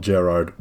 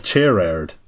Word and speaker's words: Gerard: And then there's a separate, completely separate point that said, his name is not pronounced like the typical Gerard with a long A. Ger is pronounced like chair, with the ard sounding like erd. It is pronounced like chair-erd.